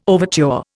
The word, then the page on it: overture: Indian